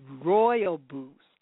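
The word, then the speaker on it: Rooibos: So we're talking about Ruaboos or Royaboos. I always say it wrong. My producer says it's Royaboos.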